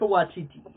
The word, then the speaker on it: Waititi: Taika Waititi. – Waititi, man. – I've heard interviews, and he puts more emphasis on the beginning of the Waititi.